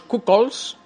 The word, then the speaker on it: cuckolds: Oye, vamos a entrar ya en, el tema más goloso que son los cuckolds, no sé si lo pronuncio correctamente. – Sí, sí, la pronuncia muy bien.